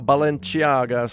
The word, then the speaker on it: Balenciaga: The miniseries Cristobal Balenciaga, scored by Alberto Iglesias. So I should pronounce that more Spanish instead of Italian.